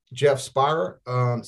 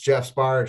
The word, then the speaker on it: Geoff Speyrer: It's G E O F F. That's Golf Echo Oscar Foxtrot. Foxtrot. The last name is S P E Y R E R.